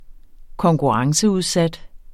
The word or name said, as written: konkurrenceudsat